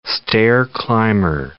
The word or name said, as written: stair-climber